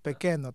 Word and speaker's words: Pequeno: Czy dobrze wymówiłem? wymówiłem w końcu do nazwiska. – Dobry wieczór. Niestety nie. E, czyli Dawid Pekeno, tak się wymawia.